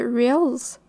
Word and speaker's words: Reels: Les Reels et les Shorts. Bonjour, c'est fait du montage. La manière que j'ai prononcé ça m'insulte tellement. Ce sont des Reels et des Shorts pas des real short, sont la version hors retard des TikTok.